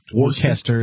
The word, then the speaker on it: Worcester: They pronounce Worcester as Worcester.